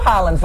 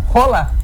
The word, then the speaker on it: Haaland: Porque não é Erling Haaland que fala o nome dele. – Como é que fala? – Rola. – Bicho! – Erling Rola. – Quem que disse isso? – Ele mesmo que fala, quando ele se apresenta, Erling Rola.